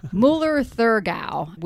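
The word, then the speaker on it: Müller-Thurgau: It's Müller Thurgard. I always forget how to pronounce. Müller Thurgau would be it.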